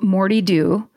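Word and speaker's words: Moddey Dhoo: My personal favorite is the Moti Doo. Oh, sorry. Pronounced Morty Doo.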